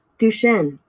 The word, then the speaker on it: Duchenne: Duchenne.